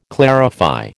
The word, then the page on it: clarify: American English